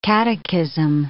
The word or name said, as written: catechism